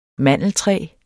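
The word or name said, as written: mandeltræ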